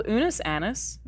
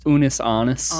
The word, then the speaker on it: Unus Annus: Did I pronounce that correctly? – No. – How is it pronounced? – Unus Anus. – Anus. – Yeah. – I'm sorry. – Latin. Anus. – Yeah, it's Latin.